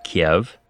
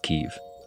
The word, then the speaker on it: Kyiv: I'm in Kyiv, which if I'm being honest, I've always pronounced Kiev, but recently learned I've been saying wrong my whole life. It's Kyiv.